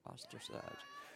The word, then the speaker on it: ostracized: How you pronounce it? Ostracized. That with an O or A? O, ox, as in ostrich. Ostracized.